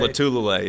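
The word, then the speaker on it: Lotulelei: I love how you say it. Lotulele? That's how you say it. – Lotulele? Latulele? – Latulele, yes. That's how you actually.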